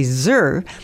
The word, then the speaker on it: Yer: And just to let you know, when I say Zur, it's actually spelled Y-E-R, but Zur in the Hmong culture is the spelling is Y-E-R, but the Y is pronounced Z.